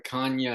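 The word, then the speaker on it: Canha: Yeah, and then also one thing about the Mets, the pronunciation of Mark it's not Mark Canna anymore. Did you see that? It's Mark. – I did. Yeah, I saw. And then his wife said, no, it's actually Canna. It's just pronounced like Kanya in like Portugal or something.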